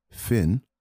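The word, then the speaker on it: Fionn: The band's name is pronounced Finn, but because it is spelt with F-I-O-N-N, I had the hardest time trying to figure out how to spell their name.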